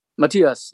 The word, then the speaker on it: Matthias: Obviously, it's you and Rudolph and Matthias. Am I pronouncing his name right? – Matthias, yeah.